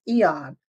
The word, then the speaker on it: AEON: Now, it's spelled A-E-O-N. Okay, so I don't want y'all to, some people say Aeon, but it's Eon patches.